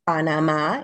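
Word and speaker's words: Panamá: So today we're coming to you from Panama, just outside of, actually, Panama, if you want to pronounce it correctly.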